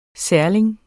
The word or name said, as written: særling